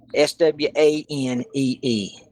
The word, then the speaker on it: swanee: S W A N E E. And it's pronounced swanee. Swanee or swanee. Well, I'll swanee.